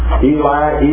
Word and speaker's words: Eloi: In Mark chapter 15, verse 35, you recall Jesus cried out, Eli, Eli, although it's spelled Eloi, Eloi, lama sabachthani.